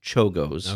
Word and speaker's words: Czolgosz: But this this Leon Chozgo, I'm having trouble pronouncing that one. – No worries. – It's spelled Czolgosz, but it's pronounced Czolgosz.